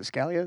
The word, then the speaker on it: Scalia: And then I would just drop an article that was from like Justice Scalia. Is it Scalia?